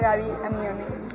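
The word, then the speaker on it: Gaby Amione: Hola Gaby, Amione. – Hola. ¿Lo dije bien tu nombre? Sí, perfecto. Okay. Así como suena.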